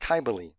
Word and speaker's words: Cybele: Some people say Cybele, depending on what part of the country, but it's pronounced Cybele.